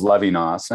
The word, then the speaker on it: Levinas: That individual is Levinas.